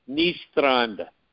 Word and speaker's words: Nystrand: You know, it's spelled G-E-I-R, new word, A-G-E, new word, N-Y-S-T-R-A-N-D. Now, Nystrand is easy. That means new beach. And that's easy because, you know, the Grand Strand, Myrtle Beach is what they call the Grand Strand. So, Ni is new, Nystrand, new beach. George Nystrand, easy, right? Just try to roll that R. George Nystrand.